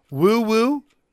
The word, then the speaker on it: Nwaiwu: Fabeci, and I think we determined based on the pronunciation guide, Woo Woo is how you say it. N-W-A-I-W-U, Woo Woo.